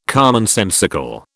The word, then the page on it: commonsensical: British English